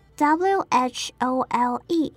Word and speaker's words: whole: 第二个要注意发音的单字为 whole 这个字，w h o l e。中 W 不发音，整个单字念作 whole。